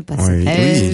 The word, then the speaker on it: Passerelle: Oui, puisque tu parles du projet, il y a le projet Passarelle. Passerelle, on ne le prononce pas comme ça? – Oui, on a plusieurs projets en fait, hein, actuels, oui. Passerelle.